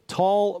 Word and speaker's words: Taal: Topic number five today, the Philippines volcano, the Taal volcano, which it certainly is, but it's spelled T-A-A-L.